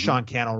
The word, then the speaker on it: Sean Cannell: And that's Sean Cannell, rhymes with YouTube channel.